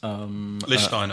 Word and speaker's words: Lichtsteiner: Jamie Carragher couldn't pronounce... – Lichtsteiner. – Lichtsteiner. Kept calling him Lichtensteiner.